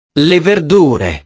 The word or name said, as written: le verdure